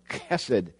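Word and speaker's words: hesed (חסד): Or maybe if you're able to pronounce it, it's more of a CH, a guttural, back of your throat consonant, hesed.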